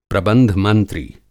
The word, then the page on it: प्रबंध मंत्री: Hindi